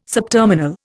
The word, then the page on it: subterminal: Indian